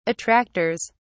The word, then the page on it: attractors: British English